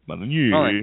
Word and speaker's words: Molyneux: Molyneux. – Yeah, no, I know. It's a thing. It's so many vowels. And an X. And none of them get pronounced.